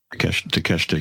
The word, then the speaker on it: Takeshita: Takeshita, yeah.